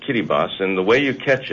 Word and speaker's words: Kiribati: Well, you say Kiribati, I say Kiribati. That's how they pronounce it, and out of respect to the people of Kiribati, I pronounce it that way. – Kiribati? – Kiribati. The T is silent in the...